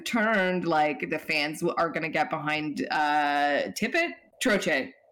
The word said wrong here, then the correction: Tocchet: Is the new coach. – No. – And it's like, guys. – Rick Tocchet. – Oh, yeah. I think I just pronounced it wrong.